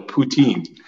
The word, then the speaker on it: poutine: So apparently, Dr. Nazir gave me a hard time because apparently I pronounced poutine wrong. Do you eat that? – You got to pout a little. Poutine.